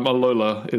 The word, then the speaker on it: Alola: Yeah, Alola is how it's pronounced,